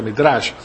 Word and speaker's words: Midrash: Entonces, además, el Mizrah, o Midrash, se pronuncia Midrash,